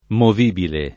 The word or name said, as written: movibile